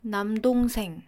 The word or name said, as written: 남동생